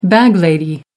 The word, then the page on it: bag lady: American English